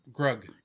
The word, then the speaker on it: Grug: And, Crew? That's Grug. – Grug. Okay. I'm terrible with names. Grud.